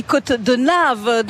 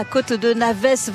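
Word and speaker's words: Côte de Naves: Et les Corréziens nous précisent qu'on dit Côte de Nave, donc on va prendre la bonne prononciation. – La Côte de Nave. – La Côte de Nave et pas la Côte de Navès, 26 secondes d'avance.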